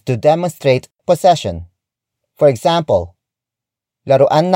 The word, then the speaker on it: laruan: Laruan ng aso yan. Laruan ng aso yan. The first word is laruan. Laruan. Laruan. Laruan is spelled as L-A-R-U-A-N.